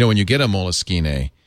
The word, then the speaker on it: Moleskine: It's spelled Moleskine. It's pronounced Moleskine.